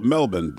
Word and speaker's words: Melbourne: I do feel weird saying it because I feel like you're trying to sound pretentious, but the thing is, if they call it Melbourne, then that's the way it's pronounced.